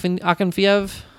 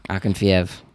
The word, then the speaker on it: Akinfeev: uh, Ak- Akinfeev. Akinfeev.